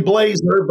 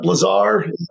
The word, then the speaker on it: Blazar: It's actually Blazar, but Blazar.